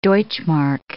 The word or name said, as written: deutschmark